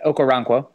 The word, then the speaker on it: Okoronkwo: He still lost a couple reps, especially when he went up against, I always get this name wrong, Okoronkwo. – You got it. Okoronkwo. You got it. – Okoronkwo. There we go.